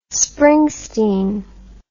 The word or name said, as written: springsteen